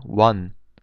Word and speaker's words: 원: The Korean currency is called won, and its symbol is W with two straight lines, and it is pronounced 원.